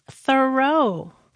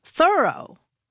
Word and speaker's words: Thoreau: All this time, I thought his name was pronounced Thoreau, but no. – How is it pronounced then? – Thoreau. – Thorough, I'm thoroughly did it. – Yeah, or I'm thoroughly surprised that it's pronounced that way.